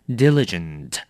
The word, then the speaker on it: diligent: 第一个字 diligent. Diligent. 形容词勤勉的。那么它就是努力的意思。那么不过这个字我要提醒你的是很多的人都会乱念哦，把它念成 diligent. 不对，这个字要重音放在最前面，要念成 diligent. Diligent. 所以下次不要再念成 diligent，这样很可怕哦。